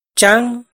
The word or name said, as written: tsaab